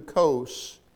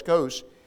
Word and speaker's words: Kos: sails to Kos, that's the way it's pronounced, C-O-S, Kos,